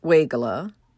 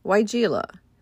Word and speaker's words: Weigela: Some pronounce it Weigela, others say Weigela.